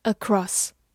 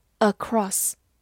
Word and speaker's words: across: Across, a c r o s s, across. 字母 a 发短音 a，c 发 k，r o 发 r，s s s, across. across，或者美式发音字母 o 长读 across 也是可以的。